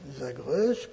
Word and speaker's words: Zagreus: Zagreus, comme ça se prononce, si vous voulez l'écrire, Z-A-G-R-E-U-S.